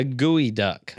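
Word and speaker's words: geoduck: It looks like one of those a gooey duck. – What? – You know, it's called a gooey duck. It's spelled like geo.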